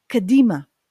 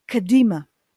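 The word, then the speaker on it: קדימה: Next line will be Ulfaate Mizrach Kahadima. And again, when we sing, we're pulling the syllables. But basically, the words are Ulfaate Mizrach kadima. We sing kadima. We're really pulling the syllables, but basically it's kadima.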